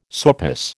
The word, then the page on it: sourpuss: American English